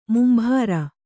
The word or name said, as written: ಮುಂಭಾರ